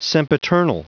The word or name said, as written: sempiternal